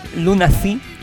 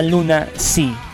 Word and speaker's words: Lunacy: Insisto en la pronunciación Lunacy porque no es la palabra Luna sí, no es el nombre Luna sí, sino es un nombre distinto.